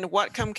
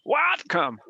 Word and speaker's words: Whatcom: What come? What come? What come? I like what come better.